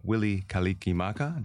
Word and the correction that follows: Mele Kalikimaka: Did I say that right? – Well, I don't know. I'm not Hawaiian, but, okay, but I know Mele Kalikimaka, but yeah.